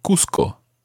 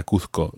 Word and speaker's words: Cuzco: I said Cusco there because it's spelled C-U-Z-C-O and I speak Spanish with a Spanish accent. But Cusco is a Peruvian town and it's much more normal in Cusco to say Cusco.